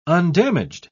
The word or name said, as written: undamaged